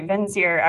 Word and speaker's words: Vincere: Okay, Vinciri. I was going like the Italian version. – It's Latin and it totally doesn't sound like that, but everyone goofed it up. So that's the way that it's pronounced now.